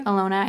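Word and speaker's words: Ilona: Alona, I think is actually how you say her name.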